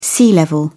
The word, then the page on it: sea level: British English